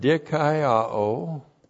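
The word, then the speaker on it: dikaiō (δικαιόω): And that is the Greek word dikaiō. Looks like that in the Greek, D-I-K-A-I-O, long O.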